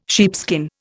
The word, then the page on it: sheepskin: Indian